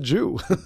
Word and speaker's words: Zhu: So in Beijing, you write it, you met someone by the name of, and I hope I'm pronouncing this correctly, Zhu, Z-H-U. – Well, she pronounced it Jew.